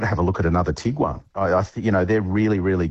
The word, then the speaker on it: Tiguan: Afternoon, I'd like to replace my Tiguan with a, is that how you say it? – Mm-hmm.